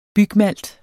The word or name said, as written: bygmalt